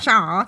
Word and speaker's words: Char: Char. – That's how it's pronounced.